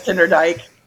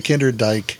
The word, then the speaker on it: Kinderdijk: All right, and we're on to Kinderdijk, and I hear, did I say that right? – That's Kinderdijk. – Okay, Kinderdijk.